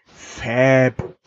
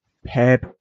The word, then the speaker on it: Pheb: Ha, das nächste Wort, du würdest, wenn du es lesen würdest, sagen Fab. Aber es ist ein P-H-E-B und wird ausgesprochen Pab.